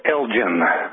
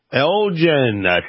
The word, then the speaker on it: Elgin: That town you mentioned, Elgin, is pronounced Elgin. – Elgin. Thank you for the correction.